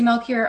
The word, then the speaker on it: Melchior: So I'll spell it real quick. It's M-E-L-C-H-I-O-R. Tracy Lindsay Melchior on Facebook and Twitter.